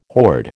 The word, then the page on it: horde: American English